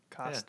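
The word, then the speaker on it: Cousteau: Kostu. Yeah, so I totally butchered that. I apologize. – Kostu, like plateau. Oh, maybe it's Kosto. Kosto, yeah, like plateau. There you go.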